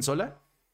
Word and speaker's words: Sola: Pero bueno, André, el proyecto para la gente que no estaba acá es el documental Sola. Sí, sí está bien pronunciado, ¿verdad? Sí lo dicen Sola.